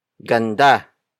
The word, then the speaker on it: ganda: Ganda. Ganda. Which is spelled as G-A-N-D-A.